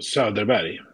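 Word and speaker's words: Söderberg: Oh, So, derberg, I would say in Swedish.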